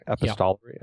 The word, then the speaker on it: epistolary: Is that how you say it? Epistolary? Epistolary? Yeah.